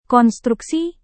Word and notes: konstruksi: Indonesian

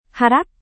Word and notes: harap: Indonesian

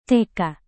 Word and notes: tk: Indonesian

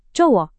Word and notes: cowok: Indonesian

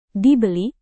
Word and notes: dibeli: indonesian

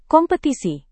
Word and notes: kompetisi: Indonesian

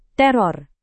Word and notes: teror: indonesian

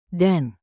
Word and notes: dan: indonesian

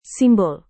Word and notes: simbol: Indonesian

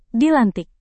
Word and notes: dilantik: Indonesian

